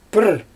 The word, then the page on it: prr: Xyzyl